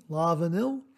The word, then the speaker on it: La Vanille: I believe it's pronounced La Vanille. I believe that's how it's pronounced. L-A-V-A-N-I-L-L-E, La Vanille.